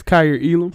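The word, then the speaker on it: Kaiir Elam: How you say his name? – It's Kyer Elam. – Kyer Elam, cornerback.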